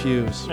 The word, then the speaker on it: Hughes: I did say it right. – Is that an official title? – It's not Hugh Gay or something like that. – No, it's Hughes.